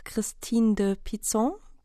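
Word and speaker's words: Christine de Pizan: Und die heißt Christine, nach Christine de Pizon. Habe ich das genau, richtig ausgesprochen? Richtig. Also, ja.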